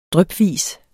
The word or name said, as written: drypvis